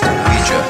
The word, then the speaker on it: Ouija: It's pronounced Ouija, not Ouija. It comes from the French and German words for yes. We. Ouija.